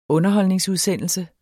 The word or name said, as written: underholdningsudsendelse